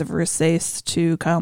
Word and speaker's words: Versace: It's beautiful, but it's pronounced Versace.